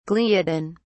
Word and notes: gliadin: British English

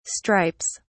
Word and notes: stripes: British English

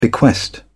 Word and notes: bequest: British English

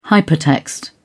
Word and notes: hypertext: British English

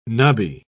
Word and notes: nubby: British English

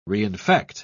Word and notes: reinfect: British English

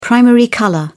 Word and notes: primary colour: British English

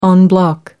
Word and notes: en bloc: American English

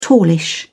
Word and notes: tallish: British English

